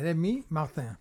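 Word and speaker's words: Rémy Martin: Στα γαλλικά τονίζονται όλα στη λίγουσα. Είναι Ρέμι Μάρτιν.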